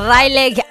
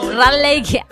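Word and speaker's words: Raleigh: Rayleigh, así se pronuncia. Es que yo ni sé ni hablar, dice. Rayleigh, así se pronuncia.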